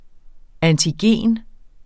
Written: antigen

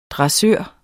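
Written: DRESSØREN

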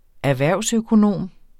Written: erhvervsøkonom

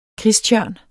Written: kristtjørn

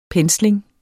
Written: pensling

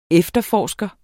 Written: efterforsker

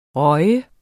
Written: røget

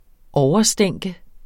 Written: overstænke

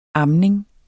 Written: amning

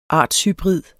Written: artshybrid

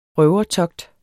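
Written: RØVERTOGT

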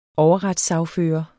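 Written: overretssagfører

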